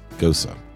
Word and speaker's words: Gose: The word is spelled G-O-S-E, but I've been told that that is pronounced Gosa.